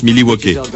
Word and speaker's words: Milwaukee: Ça se prononce Milwaukee.